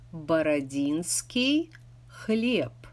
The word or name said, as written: Borodinsky Hleb